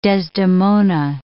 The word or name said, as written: Desdemona